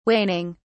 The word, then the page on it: waning: British English